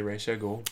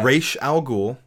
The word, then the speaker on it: Ra's al Ghul: I still say Ra's al Ghul, but... – Ra's al Ghul or Ra's al Ghul.